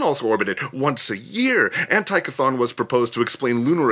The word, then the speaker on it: Antichthon: Antikython is pronounced with a hard C.